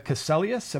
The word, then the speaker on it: Caselius: Dr. Brenda Caselius. Am I pronouncing that correctly? – That's right.